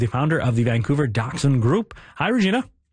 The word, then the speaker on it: Dachshund: according to the German, it's Dachshund.